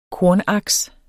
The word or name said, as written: kornaks